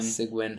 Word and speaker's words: Cygwin: Bash on Windows sounds like what we already have is the CWN. – Cygwin. – Cygwin, how you pronounce it.